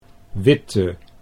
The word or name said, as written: Witte